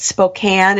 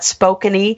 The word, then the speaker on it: Spokane: And for anybody listening, if you're not from this part of the country, it's Spokane. It's not Spokane-y, like some of the sports announcers pronounce it.